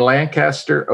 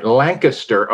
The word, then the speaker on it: Lancaster: We're coming to you today from here, there, and yonder, but I'm in Lancaster, Ohio. I didn't say that right. Lancaster, Ohio.